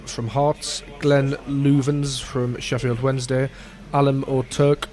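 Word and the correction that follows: Öztürk: from some Turkish club that I can't pronounce, Boluspor, Tom Flanagan, Oz-Turk. – That's the one.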